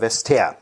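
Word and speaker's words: Vestager: Ähm, kann man aber googeln, steht auch in der Wikipedia Wester. Ja, zwei Silben und auch der Vorname hat nur zwei Silben, nämlich Margrete.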